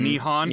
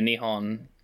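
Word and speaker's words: Nihon (日本): How do you say Japan in Japan? – You say Nihon. – Nihon? – Yeah, Nihon. N-I-H-O-N.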